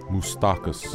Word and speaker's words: Moustakas: Moustakas. – Moulaka! I used to say that all the time when the captain was here. Don't, you're good. He was the Greek. Moustakas.